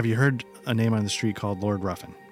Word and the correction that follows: Ruthven: Am I saying that right? Sorry, is it Lord Ruffin? – Ruthven. – Ruthven.